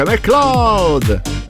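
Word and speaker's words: MacLeod: Ma anche lui lo dice McCloud. Quindi dovrebbe essere giusto.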